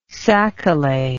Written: sacalait

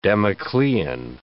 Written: Damoclean

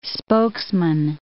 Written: spokesman

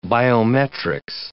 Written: biometrics

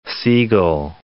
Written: Segal

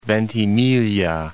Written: Ventimiglia

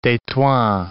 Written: Tetouan

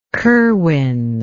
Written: kerwin

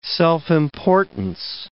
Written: self-importance

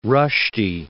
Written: Rushdie

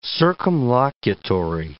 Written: circumlocutory

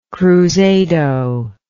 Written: cruzado

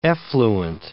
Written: effluent

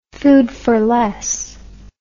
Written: food fish

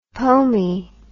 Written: pomey

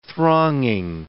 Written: thronging

